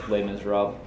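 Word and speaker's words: Les Misérables: the Les Miserables.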